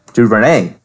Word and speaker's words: DuVernay: Anyways, 13 written by Spencer Averick and Ava DuVernay. Did I pronounce that right? – DuVernay.